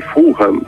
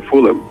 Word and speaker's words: Fulham: Inclusive, a pronúncia certa é que é o estádio do Fulham, né? Todo mundo chama de Fulham, mas eu aprendi aqui que é Fulham.